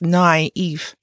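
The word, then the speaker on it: naïf: The naive, N-A-I-F, pronounced naive, is a narrator who is naive.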